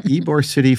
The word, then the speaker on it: Ybor City: It takes place in, Ybor City. I always mispronounce that. Ybor City,